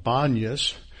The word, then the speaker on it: Banyas: called Banyas, because Arabs can't pronounce the letter P. So instead of saying Pan, they would say Ban.